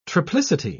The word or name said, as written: triplicity